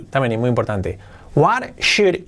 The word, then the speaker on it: should: Deberías, should, pero modal, should, no se pronuncia sold, no, should, sin la L.